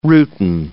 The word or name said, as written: rutin